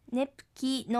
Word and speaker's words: ネプキ: ネプキは仕事、ネプキのキはきちんと発音しましょう。